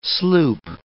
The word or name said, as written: sloop